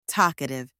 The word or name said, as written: talkative